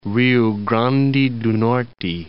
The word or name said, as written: Rio Grande do Norte